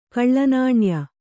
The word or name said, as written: ಕಳ್ಳನಾಣ್ಯ